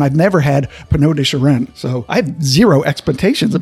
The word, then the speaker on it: Charentes: I was focusing on that very hard to pronounce French white wine cask that you were talking Charentes.